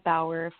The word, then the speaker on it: Bauer: And that's just my full name, which is on the thumbnail, Crystal Bauer Feldman. – All right. – Spelled like the skate, B-A-U-E-R.